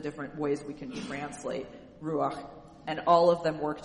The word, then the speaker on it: ruach: So the Hebrew word for this is, it's ruach. That's just how you would find it written out in the Bible. That's how you say it, ruach.